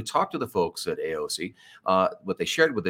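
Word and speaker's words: Eossi: it's e-o-s-s-i by the way mm-hmm. pronounced aoc it's a luxury vegan plant-based skincare products uh, it's us source hemp it's formulated and made right here in Colorado.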